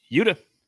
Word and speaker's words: Yuta: Yuta? – Yuta.